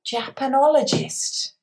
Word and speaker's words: Japanologist: And she's also a Japanologist. Did I say that right, Beth? – Yes.